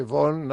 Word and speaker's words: Vaughan: Carrying the torch for Lo que me sorprende, es que no le hayas corregido la pronunciación de Sarah Vaughan. – Ha dicho... Von. Lo que pasa es que si dice Von, no sabemos que es Sarah Vaughan.